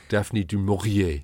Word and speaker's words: du Maurier: As she insisted on pronouncing it. Daphne du Maurier.